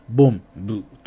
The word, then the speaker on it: BOMB: ボン、ブというようなわけで、 BOMB と書いて、えボンブと、え読みます。